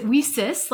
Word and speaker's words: WiCyS: So Women in Cybersecurity, we often go by our acronym, WICYS, and we pronounce it WICYS, like we sisters, because that's exactly what we are.